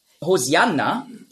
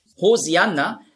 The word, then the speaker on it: Hosianna: Hosianna ist einfach die griechische Aussprache von Hoshi'anna. Hosianna, das ist die griechische Aussprache.